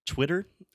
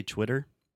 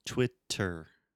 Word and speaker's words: Twitter: So when we say Twitter, if you ask an American, how do you say this social media platform? Maybe they will say Twitter, ter, with a true T, like table, time, really making depends the on the region that this American lives.